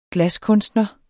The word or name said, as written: glaskunstner